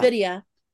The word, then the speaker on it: NVIDIA: NVIDIA.